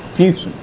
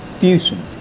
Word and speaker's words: Füssen: Füssen. – Oh, Houston. – Füssen. – How you spell it? – F-U-S-E-N.